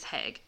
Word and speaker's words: Hague: Also, I looked it up and it is Hague. It says rhymes with plague.